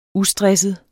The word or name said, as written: ustresset